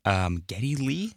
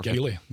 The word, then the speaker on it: Geddy Lee: As much as I love Robert Plant and Ozzy, I'm actually picking Geddy Lee, if I'm saying that right. Am I saying – That right? – Yeah, you're saying exactly okay, right. – Perfect. – Geddy Lee.